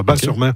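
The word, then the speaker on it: Batz-sur-Mer: On prononce Bas, le T ne se prononce pas. Bas-sur-Mer.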